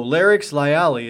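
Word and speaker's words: Larix lyallii: So Larix lyalli is how you pronounce the scientific name, the alpine larch.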